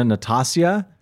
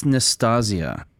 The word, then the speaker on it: Nastasia: The artist is Nina Natasia. How would you pronounce that? – I recently heard that it's Nastasia. – Nastasia. I forgot one of the S's.